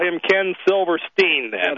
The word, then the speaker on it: Silverstein: Whenever it's S-T-E-I-N, that's a German, and the Germans pronounce it Stein. Well, the Yiddish don't like to pronounce their names in a German fashion, even if it's spelled that way, so it's always, they pronounce their names Stein, Goldstein, Silverstein, etc. – Okay, well, he spells his name S-I-L-V-E-R-S-T-E-I-N.